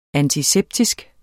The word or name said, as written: antiseptisk